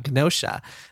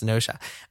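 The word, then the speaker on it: Gnosia: Gnosha, with a G. I think it's just pronounced Gnosha.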